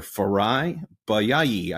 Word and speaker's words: Farayi Bayayi: Pastor Farayi, Bayayi.